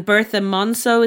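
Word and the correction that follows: Berthe Morisot: Is that how you pronounce her? – Bertha Morisot. Yeah.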